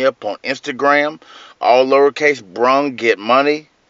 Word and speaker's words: Brung: Without the FTG, just capital B, lowercase R-U-N-G, Brung. That's how you say it, Brung, like I brung you something that you needed.